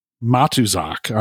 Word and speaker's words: Matuszak: John Matuszak, Matuszak. I hope I'm saying his name right. – Sounds right.